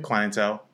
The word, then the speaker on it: Clientele: And you could tell that like, all right, like Supreme Clientele was like, am I saying that right? – Clientele.